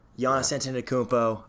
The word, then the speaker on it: Giannis Antetokounmpo: Giannis Antetokounmpo. Yep, I pronounced that right.